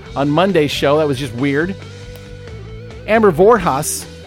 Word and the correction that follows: Vorvas: Well, Vorvas, I'm sorry. I managed to murder her name again.